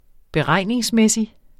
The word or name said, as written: beregningsmæssig